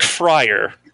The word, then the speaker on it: Fryer: Think Fryer. Okay. Like, you know, Fryer Tuck, Fryer Lawrence from Romeo and Juliet, Deep Fryer, you know, whatever works.